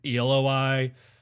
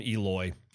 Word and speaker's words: Eloi: I call them Eloi. Other people call them Eloy.